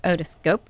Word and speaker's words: otoscope: Okay, so a video otoscope, did I say that correctly? – Yep.